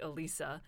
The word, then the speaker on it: Elissa: So growing up, the way that I heard my name pronounced was Elisa. – Bonjour, Elisa. – Oui, exactement comme ça. So like in English, the closest approximation would be like Alisa.